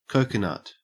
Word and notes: coconut: Australian English

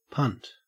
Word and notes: punt: Australian English

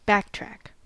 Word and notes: backtrack: American English

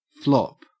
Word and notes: flop: Australian English

